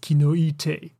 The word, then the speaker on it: Kinoite: The second option is to use the Japanese Latin pronunciation, which is Kinoite, which means in Japanese that there is a tree,